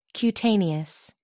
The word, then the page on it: cutaneous: American English